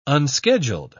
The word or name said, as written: unscheduled